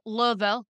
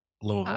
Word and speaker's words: Louisville: I had to go from Lexington to Lexington, Kentucky to Louisville, Kentucky, or Louisville. – Louisville. – How they say it? You gotta like swallow it. – Louisville.